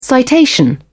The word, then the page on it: citation: British English